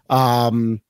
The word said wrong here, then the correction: Abimelech: Now you screwed me up. Abimelech. – Abimelech. There we go.